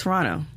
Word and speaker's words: Toronto: So we're about a week and a half away from NXT TakeOver Toronto, It's Toronto. You don't put emphasis on the toe. If you say Toronto, then that means you've never been to Toronto.